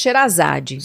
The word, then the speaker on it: Sherazade: No Brasil se pronuncia Xerazade.